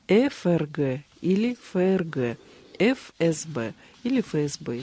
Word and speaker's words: ФСБ: Дело в том, что при такого рода аббревиатурах мы должны произносить буквы так, как они называются в алфавите. И действительно, с точки зрения правильной русской речи, мы должны говорить ФРГ и ФСБ, потому что название буквы звучит как F.